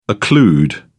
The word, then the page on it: occlude: British English